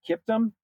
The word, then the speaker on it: Kiptum: But there was this Kenyan, uh, and I'm going to make sure I get his name right. Uh, it's Kelvin uh, Kiptum, K-I-P-T-U-M, Kelvin Kiptum from Kenya.